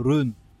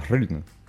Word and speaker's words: Hraun: mais je crois, que ça se, prononce genre euh, run ». Comme ça. – En fait, nous on dit, on mais ça se dit Rune.